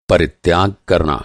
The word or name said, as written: परित्याग